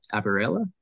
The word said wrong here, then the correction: Arabella: Arabella. – Arabella. Yeah, Arabella.